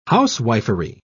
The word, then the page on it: housewifery: British English